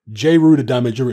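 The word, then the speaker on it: Jeru the Damaja: Just look up J.Ru the Damager. It's spelled J-E-R-U. And Damager is spelled D-A-M-A-J-A.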